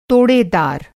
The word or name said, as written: तोड़ेदार